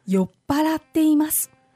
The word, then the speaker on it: 酔っ払っています: 酔っ払っています.